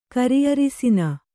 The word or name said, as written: ಕರಿಅರಿಸಿನ